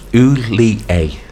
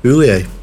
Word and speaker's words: Hollier: Yes, pronounced Oulier. – Oulier. All right. – Oulier. Yeah. – I just like Gerrard. – Apparently so, yeah, that's what it says on his website.